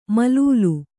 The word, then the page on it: ಮಲೂಲು: malūlu